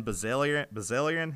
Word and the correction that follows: Bilzerian: How do you say that name? – Bilzerian.